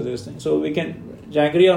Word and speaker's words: jaggery: But jiggery, I never know how to say it. That's okay for everyone to have, right? As a sugar replacement. – As a refined sugar replacement, you can go with, like, jaggery and...